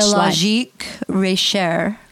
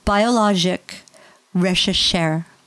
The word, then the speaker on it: Biologique Recherche: That French Biologique Recherche. – Yes. – Recherche. I literally learned how to say it properly, and now I'm going to say it wrong. It has two, it's Biologique Recherche.